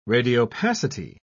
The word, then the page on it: radiopacity: British English